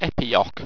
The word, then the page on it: epiock: Owstwecian